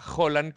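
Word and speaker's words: Holan: Ese Claro, Que es curioso porque se escribe H-O-L-A-N y se pronuncia Holland como si fuera una J.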